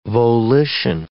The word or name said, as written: volition